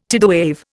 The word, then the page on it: tidewave: Indian